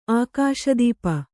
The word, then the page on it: ಆಕಾಶದೀಪ: ākāśa dīpa